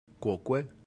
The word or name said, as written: quoque